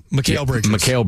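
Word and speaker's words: Mikal: Is it Michael Bridges or? – Mikhail Bridges.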